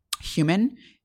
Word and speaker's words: HVMN: The company is called Human. But it's spelled like HVMN, and they've already gone public with this ketone ester, which will be available in February.